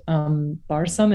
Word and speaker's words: barsum: And this word like is really similar to this Zoroastrian word, barsum. Is that how it's said, Yasmina? – Yes.